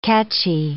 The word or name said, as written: catchy